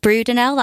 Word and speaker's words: Brudenell: And obviously, speaking of headlines, you've just announced your one at Leeds Brudenell. That's how you pronounce it, isn't it? – Yeah.